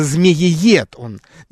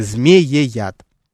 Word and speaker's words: змеияд: Хотя вот к самому названию, наверное, есть претензии, да, наверное, можно подумать, наверное, я ошибку делаю какую-то, да, наверное, змеиед он. Нет, действительно, друзья, пишется и произносится именно змеияд.